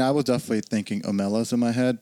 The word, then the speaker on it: Omelas: And I want to ask y'all a question because I thought the pronunciation was Omelas, but apparently it's Omelas.